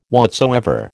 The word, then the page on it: whatsoever: American English